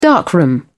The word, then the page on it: darkroom: British English